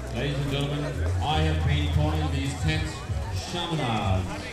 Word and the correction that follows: shamiana: but that shows a terrible cultural oversight on my part. They're actually pronounced Shamiana. That right? Shamiana.